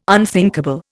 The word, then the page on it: unthinkable: Indian